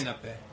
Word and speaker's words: sinape: How do you pronounce that? – Sinape. – Oh, thank you. With the Italian.